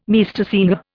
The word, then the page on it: Meistersinger: Indian